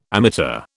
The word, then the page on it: ammeter: British English